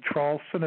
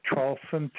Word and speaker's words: Traulsen: My name is Hugh, H-U-G-H. The last name, Charlson, is spelled T like Tom, R-A-U-L-S-E-N like Nancy. And there's a big website, if you just Google three words, my last name, Charlson, T-R-A-U-L-S-E-N, the word and, A-N-D, and then Trump.